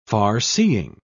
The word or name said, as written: farseeing